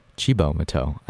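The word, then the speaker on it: Cibo Matto: But up next, we're back with Chibo Mato. I learned that's how it's pronounced in between songs because I looked it up on Google.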